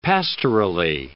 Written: pastorally

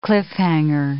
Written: cliff-hanger

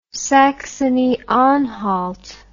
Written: saxony anhalt

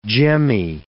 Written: gemmy

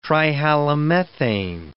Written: trihalomethane

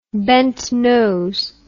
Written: bentnose

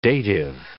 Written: dative

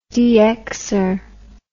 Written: dxer

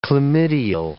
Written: chlamydial

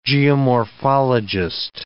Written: geomorphologist

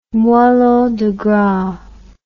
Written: moellon degras

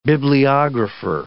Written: bibliographer

